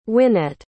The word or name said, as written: wynette